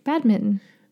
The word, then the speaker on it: badminton: Badminton. – We say badminton. – It's badminton. – Badminton. – What did you say? – Badminton. – Oh, you pronounce both T's.